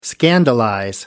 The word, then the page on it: scandalize: American English